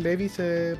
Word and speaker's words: Levi's: bueno, yo, yo por Shingeki no caché que los pantalones Levi se pronuncian Levi's.